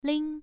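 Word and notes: ling: tone 1

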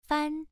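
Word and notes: fan: tone 1

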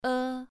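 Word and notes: e: tone 2

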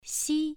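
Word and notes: xi: tone 1

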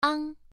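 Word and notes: ang: tone 1

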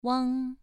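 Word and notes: wang: tone 2

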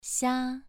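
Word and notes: xia: tone 2

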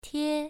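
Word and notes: tie: tone 1

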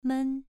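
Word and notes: men: tone 2

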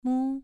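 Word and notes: mu: tone 2

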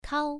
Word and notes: kao: 1

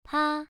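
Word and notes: pa: tone 1

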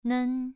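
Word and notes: nen: tone 2

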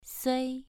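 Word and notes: sui: tone 2

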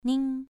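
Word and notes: ning: tone 2

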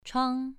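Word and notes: chuang: tone 2

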